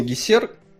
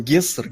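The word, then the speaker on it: Гесер: Я его всегда читал Гессер, а тут он Гессер, Гессер. – Ну, потому что в песне он должен э, рифмоваться с СССР. Поэтому его так и произносят.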